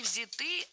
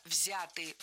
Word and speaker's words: взяты: Не взяты, а взяты. Ударение в этом слове сохраняется на первом слоге. Он взят, оно взято, они взяты. И только лишь в женском роде ударение переносим на окончание. Она взята.